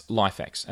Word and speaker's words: LIFX: And that's LifeX, and that's spelled LIFX.